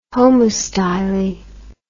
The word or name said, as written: homostyly